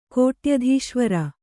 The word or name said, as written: ಕೋಟ್ಯಧೀಶ್ವರ